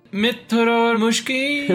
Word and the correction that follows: mrityor: No, it is mrityor mukshya. Mrityor. Yes. Mrityor.